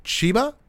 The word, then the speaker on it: Chiba: The show, which is set to go underway as of the 25th of September at Makuhari Messe in Chiba? How do you pronounce that, Max? – I think that's right. I think you nailed it.